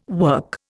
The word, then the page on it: work: Indian